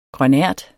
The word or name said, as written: grønært